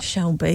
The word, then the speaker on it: Shelby: Well, of course, if you watch Peaky Blinders, the DeSalby, he's in charge, isn't he? Something like that. – Shelby, yeah, it's close.